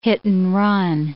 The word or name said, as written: hit-and-run